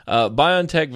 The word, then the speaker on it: BioNTech: In a small trial, the Pfizer BioNTech, which I learned is how you pronounce their name, BioNTech vaccine fully protected people from symptomatic COVID-19 caused by the worrisome B1351 coronavirus variant wildly circulating in South Africa.